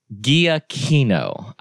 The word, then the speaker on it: Giacchino: Michael, it's Giacchino. I remember now. That's how Mr. Collins pronounces it. That's how Bobby Roberts pronounces it. That's how the majority of people who I think might have an idea of how to pronounce things pronounce it. So we're sticking with house rules.